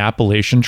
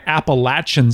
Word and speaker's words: Appalachian: People were talking about it, and they didn't like the way Appalachian sounded. They thought it sounded stupid and simple and poor. And so they changed the pronunciation so it sounded better to Appalachian Trail.